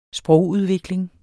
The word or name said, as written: sprogudvikling